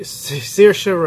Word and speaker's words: Saoirse: Saoirse, Saoirse Ronan, that's how you say it, for Lady Bird, and Meryl Streep in The Post.